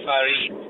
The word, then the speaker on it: Farid: I'm uh, Farid.